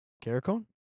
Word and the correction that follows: Carcone: Carcones? Not very good at pronouncing. There's a lot of hard hockey names. – Carcone? – Carcone? – Carcone? – That looks right.